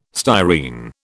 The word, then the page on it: styrene: British English